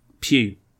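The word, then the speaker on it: Pugh: Pew. It's pronounced Pew. – He is of Welsh ancestry. – Oh. Hence the Pew.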